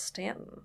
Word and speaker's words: Staunton: Then it was Mary Baldwin College, and pronounced Stanton. – Stanton. – Even though there is a U in it.